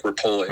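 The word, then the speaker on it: Repole: I mean, and it's pronounced Mike Repele.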